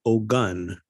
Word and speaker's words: Ogun: Well, most people spell it O-G-A-N. That's the most common misspelling. O-G-A-N. But I'm not saying Ogan, I'm saying Ogun, like gun.